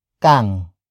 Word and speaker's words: kang: Wala kang kapatid ano? Wala kang kapatid, ano.